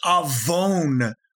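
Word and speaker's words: avon (עָוֹן): The Hebrew word is avon. It's pronounced, or it's written A-V, as in victory, O-N in English. Or I'm sorry, in Hebrew, actually.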